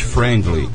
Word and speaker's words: friendly: E Socorro também são destinos pet friendly. Como é que fala isso, José? Friendly. – É isso aí mesmo.